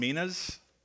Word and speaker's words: minas: And we'll also follow this story up next week with this story, this parable of the 10 minas. Yes, that is the way you pronounce that, by the way. It's a mina bird, but these are minas.